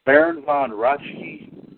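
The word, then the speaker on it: Baron Von Raschke: We're going to say Baron Von Rachke, is that how you pronounce his name? – Yep.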